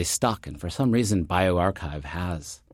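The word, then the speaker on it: bioRxiv: Yeah, I asked people on Twitter because, you know, physicists know how to say archive, but you... One of the more minor issues about starting a preprint server is how to pronounce it. So I remember hearing somebody call BioArchive Biarchix or something like that.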